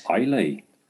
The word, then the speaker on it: Islay: Islay.